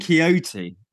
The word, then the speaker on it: Quixote: Don, Quixote. Don Quixote.